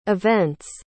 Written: event's

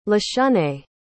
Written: lashunay

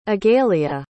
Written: agalia

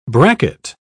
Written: bracket

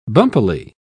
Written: bumpily

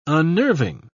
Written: unnerving

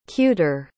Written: cuter

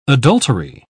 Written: adultery

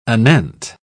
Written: anent

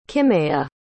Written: kimeya

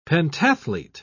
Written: pentathlete

